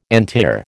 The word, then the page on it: antiar: American English